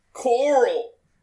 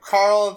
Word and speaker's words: Carl: Coral! – Yeah. His bad pronunciation of Carl.